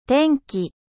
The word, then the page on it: temps: French